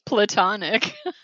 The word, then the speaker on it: platonic: She's like, you are joking when you say plutonic, right? And I'm like, what do you mean joking? Well, obviously you would joke because that's not... How do you say plutonic? – Platonic. – Oh, Jesus fucking kill yourself, Christ. Are you kidding me? – It's not Pluto.